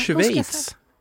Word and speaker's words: Schweiz: Men kul för, jag vill inte ens säga Schweiz. Jag får alltid höra att jag uttalade fel. Och jag förstår inte riktigt. – Du gör ju tyvärr det. – Ja. – Men hur ska jag säga, lär mig det? – Schweiz. – Schweiz. Alltså med ett så som det stavas. – S-C-H.